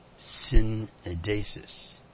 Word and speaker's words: syneidesis: That word, conscience, let me spell it for you, is S-Y-N-E-I-D-E-S-I-S. Synedesis. Synedesis.